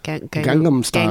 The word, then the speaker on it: Gangnam Style: Kind of like an earlier version of like what maybe we would know like Gangnam Style, right? Like, is that how you say it? – Gangnam Style.